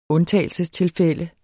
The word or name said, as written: undtagelsestilfælde